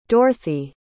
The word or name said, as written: dorthey